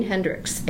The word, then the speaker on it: Hendricks: Jean Hendricks. H-E-N-D-R-I-C-K-S.